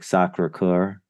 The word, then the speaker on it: Sacré-Cœur: Whereas you go into the other ones like Sacré-Cœur in Montmartre. Am I pronouncing that right, Robert? – Yeah, and then you got to have your elbows out.